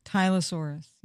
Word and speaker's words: Tylosaurus: Thing, this Tylosaurus, vicious. – Tylosaurus, yeah. – Yes. – How do you spell it? T-Y-L? – T-Y-L-O.